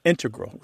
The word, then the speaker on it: integral: But the mathematicians and math using scientists are more likely to integral with the stress on the first syllable.